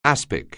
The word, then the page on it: aspic: anglais